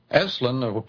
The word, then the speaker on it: Esalen: Esalen, pronounced like wrestling, where people go out to wrestle with their inner problems.